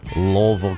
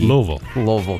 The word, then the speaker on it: Louisville: I was in, and you have to get this pronunciation right, I was in Louisville, Kentucky. Louisville, Kentucky, mm-hmm.